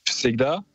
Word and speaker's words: Всегда: Всегда.